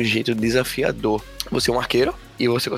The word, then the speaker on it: Archero: O nome dele é Arqueiro, se escreve A-R-C-H-E-R-O.